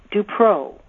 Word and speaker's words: DuPrau: But it's pronounced Dupreau with a long O. Think of the word pro, as in tennis pro or golf pro.